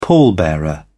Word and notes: pallbearer: British English

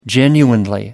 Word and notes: genuinely: British English

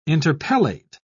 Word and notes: interpellate: British English

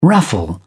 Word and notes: raffle: British English

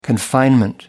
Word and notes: confinement: American English

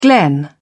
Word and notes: glen: British English

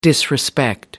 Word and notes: disrespect: American English

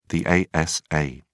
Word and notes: asa: British English

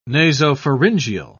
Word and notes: nasopharyngeal: British English

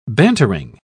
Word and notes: bantering: British English

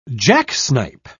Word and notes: jacksnipe: British English

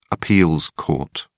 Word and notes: appeals court: British English

